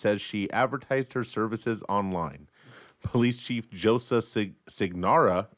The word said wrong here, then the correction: Sinagra: Did I get that? – No. – Sinagra. – Sinagra. – I knew I said it wrong. Police Chief Joseph Sinagra tells the newspaper that she identified herself as the performer's sister when she was arrested.